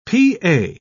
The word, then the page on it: p.a.: British English